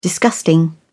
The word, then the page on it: disgusting: British English